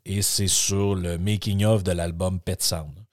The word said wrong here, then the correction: Pet Sounds: Donc Pet Sound qui est le Pet Sounds, il y a un S, il faudrait que je le prononce,